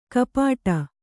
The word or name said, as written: ಗುಪ್ಪಟ್ಟೆ